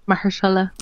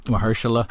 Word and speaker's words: Mahershala: Marshall, I hope I pronounced his name right. I'm glad he, Mahershala. – Mahershala.